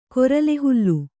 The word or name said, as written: ಕೊರಲೆಹುಲ್ಲು